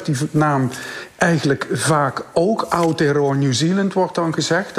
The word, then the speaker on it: Aotearoa: Eh, het is uh, eh, Aotearoa. Het, ze mag dat gewoon op zijn Nederlands uitspreken, zou ik zeggen.